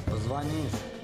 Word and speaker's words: позвонишь: Позвонишь. – Чё? – Позвонишь. Нет такого слова «позвонишь». – Сейчас такого слова нет, речь о закрепленной норме, а в будущем форма с ударением на «о» возможно появится.